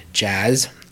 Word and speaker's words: Jass: Katie, then Julia Othmer, Nathan Corsi, Jazz, which I have been mispronouncing every time I've ever said her name. The spelling is J A S S. I've pronounced it as Jass, but it is indeed Jazz. I am sorry.